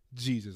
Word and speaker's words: Jesus: So like decided, all right, I'll name you Jesus, but it has to be pronounced Jesus.